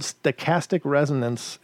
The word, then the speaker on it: stochastic resonance: And it works on the principle, and you may have to look this up if you're not familiar with it, but basically it's called stochastic resonance, if I'm pronouncing it right. – Correct.